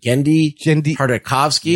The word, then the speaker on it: Genndy Tartakovsky: Is it Genndy Tartakovsky? – Yes.